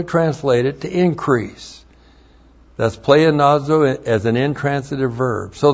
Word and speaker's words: pleonazo: And then the hina plus the subjunctive of pleonazo. P-L-E-O-N-A-Z, long O. Pleonazo.